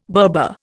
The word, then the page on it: Berber: Indian